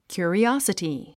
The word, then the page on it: curiosity: kjù ə riάsəti; キュリォサティ